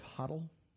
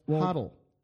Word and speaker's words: HODL: You want to talk about the pronunciation of HODL? HODL, guys. HODL. – HODL, not HODL. – Yeah, I want to do a lesson on that. It's HODL. It's not HODL.